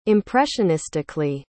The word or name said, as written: impressionistically